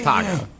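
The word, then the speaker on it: Taako: His name is Taco.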